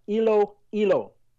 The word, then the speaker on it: Iloilo: it is pronounced as Iloilo.